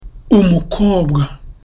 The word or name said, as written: umukobwa